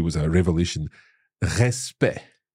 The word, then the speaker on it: respect: And at the end of the word, so E-C-T. So yep. C-T, two consonants. So E, respect. – Respect, with a silent C in there.